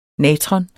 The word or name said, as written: natron